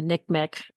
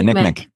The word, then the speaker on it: NCMEC: I always pronounce them NCMEC as a acronym. – Yeah, NCMEC.